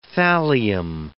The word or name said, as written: thallium